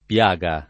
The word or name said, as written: piaga